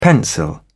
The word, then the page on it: pencil: British English